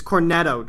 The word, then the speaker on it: Cornetto: Well, like, the same actors you'll see throughout the Cornello trilogy, Actually, let me look that up to make sure I'm pronouncing that correctly. – Cornetto? – It's Cornetto Trilogy. – Cornetto. – Wow, I suck.